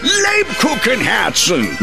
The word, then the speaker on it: Lebkuchenherzen: Lubkuchen Herzen. – The Kupenhergen Herzen. – Lubkuchen Herzen. – Nine! Lebkuchenherzen!